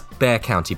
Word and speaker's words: Bexar: You were saying that's Bear County, but with an X in it. – Bear County, yeah. – Okay. – the X is silent.